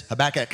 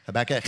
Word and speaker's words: Habakkuk: The prophet Habakkuk in chapter 3, verse 17, uh, the uh, the cat pronunciation of that is Habakkuk. Habakkuk.